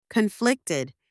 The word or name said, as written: conflicted